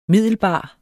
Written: middelbar

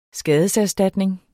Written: skadeserstatning

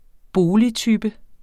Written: boligtype

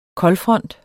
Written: koldfront

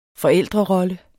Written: forældrerolle

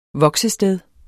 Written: voksested

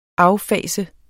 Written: affase

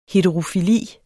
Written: heterofili

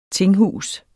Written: tinghus

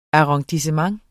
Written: arrondissement

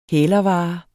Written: hælervare

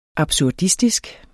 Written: absurdistisk

